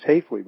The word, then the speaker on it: Hafley: And another thing I'd like to point out, since we're on the subject of Hafley, it's pronounced Hafley, by the way. It's just like Hafley always says, it's just like calling a flea, Hafley.